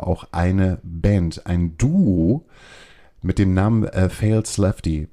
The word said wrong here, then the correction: False Lefty: wenn ich das richtig ausspreche. Fails Lefty. – False Lefty. – False Lefty. Genau.